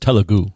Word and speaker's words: Telugu: I think it's pronounced Telugu. – Telugu? Telugu? Telugu. Yeah. Telugu.